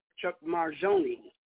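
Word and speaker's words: Mangione: We're going to jump to a track by an artist that I just was wild about, Chuck Marjone. And some pronounce it Chuck Marjone.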